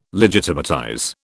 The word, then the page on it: legitimatize: British English